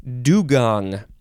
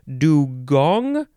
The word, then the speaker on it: dugong: Dugong. You can say, how do you pronounce this? There's a couple of ways. Dugong, I think. Like a gong that goes bong. Yeah, dugong or dugong. D-U-G-O-N-G.